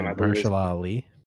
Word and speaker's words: Mahershala Ali: I don't want to butcher the actor's name. – Mahershala Ali. – There you go.